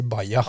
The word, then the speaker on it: Baja: Men uttalet Baja, alle sier Baja.